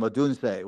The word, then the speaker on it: Odunze: and Rome Odun. Now I can't say his name, Odunze, whatever.